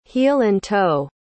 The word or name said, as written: heel-and-toe